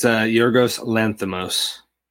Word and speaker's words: Yorgos Lanthimos: Yorgos Lanthimos. I have it written down, but I don't know if I can pronounce it. – It's Yorgos Lanthimos.